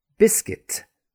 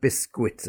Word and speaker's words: biscuit: As I said, there's also the silent U. And that comes in words such as guest, the guest in the house or a guest house. Biscuit, not biscuit, as some people say, biscuit.